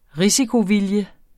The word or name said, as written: risikovilje